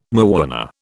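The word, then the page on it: mañana: British English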